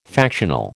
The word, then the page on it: factional: American English